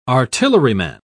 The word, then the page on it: artilleryman: British English